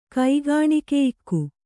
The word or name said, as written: ಕೈಗಾಣಿಕೆಯಿಕ್ಕು